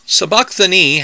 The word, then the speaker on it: Sabachthani: Sabachthani has two accents, one on the second and on the last syllables. The way that we say the bumblebee, except that strictly speaking, the stronger emphasis should be on the last syllable, like the end of the phrase, I hurt my knee.